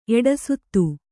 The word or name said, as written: ಎಡಸುತ್ತು